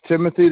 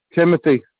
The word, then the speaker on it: Timothy: That's Timothy. That's Tango India Mike Oscar Tango Hotel Yankee. – Say that again. – Tango India Mike Oscar Tango Hotel Yankee. – Your first name? – Timothy.